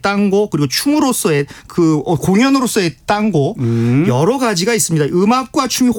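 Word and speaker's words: tango: 근데 탱고는 그 영어식 발음이고 현지에서는 그 땅고라고 합니다. – 땅고. 아, 스페인식으로 읽으면 그렇게 읽는 게 맞죠.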